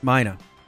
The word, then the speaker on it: Mina: Mina. – Mina Burns. – Yep. Rhymes with Gina.